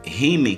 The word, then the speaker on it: Hume: O próprio Rime, que é o Rime se escreve H-U-M-E,